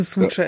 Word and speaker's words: Zwutscher: Also würde ich das auch schon Zwutscher aussprechen. – Also Zwutscher ist okay.